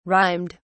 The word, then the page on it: rhymed: British English